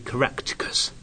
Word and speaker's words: Caractacus: Well, let's pronounce it right, shall we? Caractacus. Nobody can manage it.